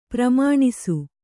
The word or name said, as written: ಪ್ರಮಾಣಿಸು